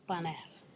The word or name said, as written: panel